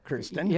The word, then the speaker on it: Kirstjen: Just look at your name, Kirstjen. – Yeah. – There's a silent J in your name.